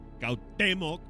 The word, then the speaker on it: Cuauhtémoc: Según aseguraba Pachita, el espíritu del tlatoani Cuauhtémoc, con acento en la E, poseía su cuerpo para guiarla en sus procesos curativos.